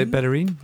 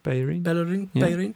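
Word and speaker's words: Bellerín: and that is a guy called Hector Bellerin. Is that how you pronounce it? – Yeah, so Bellerin, yeah. Bellerin.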